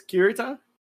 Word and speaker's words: Kurita: And like I always mispronounce his name, so say me again. It's Kurita.